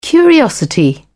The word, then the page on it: curiosity: British English